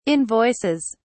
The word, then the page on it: invoices: British English